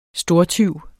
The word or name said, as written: stortyv